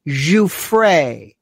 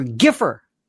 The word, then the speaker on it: Giuffre: Learn how to pronounce Giuffre. Virginia Giuffre. One moron called her Giffer.